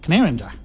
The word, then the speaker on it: Canowindra: And she said she's from Canandra, Australia. And I looked it up, and it's spelled C-A-N-O-W-I-N-D-R-A. Looks like Canowindra, but it's pronounced Canandra.